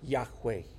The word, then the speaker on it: Yahweh: Pues, muchos de nosotros ya sabemos que el nombre del Señor, y de hecho algunas traducciones dice Jehová, que es una manera de pronunciar lo que son 4 letras en hebreo, que más hoy en día han reconocido que tal vez mejor se pronuncia Yahweh.